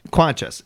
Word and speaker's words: Quenches: Quenches. – It is Quenches. – Quenches. Like quenches your thirst.